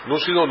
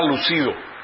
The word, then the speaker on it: lúcido: Lúcido, lúcida, esta segunda que está aquí. El de arriba era lucido, lucido o lucida, que luce bien. Pero es lúcido, con el acento en la U, que discurre o expresa con gran claridad y rapidez.